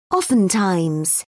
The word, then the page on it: oftentimes: British English